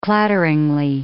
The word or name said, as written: clatteringly